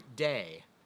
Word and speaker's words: dei: And the word that John uses here is the Greek word dei. We'd spell it D-E-I, kind of like the Latin word dei that means God, but it's the Greek word dei, and it can be translated as a necessity or an obedient response to a command.